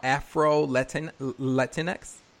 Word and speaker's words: Afro-Latinx: So I want to make sure that I'm saying this correctly. You are Afro-Latinx? – Yeah.